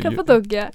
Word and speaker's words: Cappadocia: Because I've heard of this place called, again, correct me if I'm wrong mm-hmm. – In pronouncing this thing, Cappadocia. – Cappadocia.